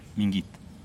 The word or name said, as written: mingit